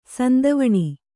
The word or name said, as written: ಸಂತವಣ